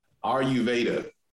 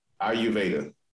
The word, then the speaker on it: Ayurveda: Ayurveda. Ayurveda. – Ayurveda.